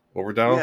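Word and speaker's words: Øvredal: It's Andre Overdat, I think. Overdal? – Yes, perfect.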